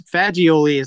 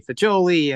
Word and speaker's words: Fagioli: You know, it's Fagioli is the way we say it, but lots of people say Fagioli and every other variable of that.